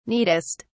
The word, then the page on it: neatest: British English